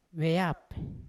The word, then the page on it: veyap: Yawa